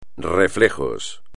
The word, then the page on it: reflejos: espagnol